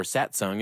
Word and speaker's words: Satsang: Satsang, or Satsung is how you say it. It's spelled Satsang, S-A-T-S-A-N-G.